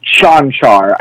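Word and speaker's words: Čančar: I think that I pronounced that right. – It's Chanchar. Okay. It's very weird of a name. It's not everyone on the face of the earth has gotten that name wrong.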